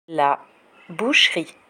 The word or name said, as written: la boucherie